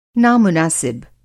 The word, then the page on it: नामुनासिब: نامُناسب